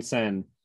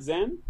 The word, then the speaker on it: Sen: Oh, it's not Zen? – No, it's Sen. S-E-N.